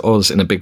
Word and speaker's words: Oz: Otzi. Is that I may be pronouncing that incorrectly. – Wizard of Oz. Wizard of Oz, I believe, is the common pronunciation.